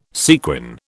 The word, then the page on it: sequin: British English